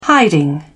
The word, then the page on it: hiding: British English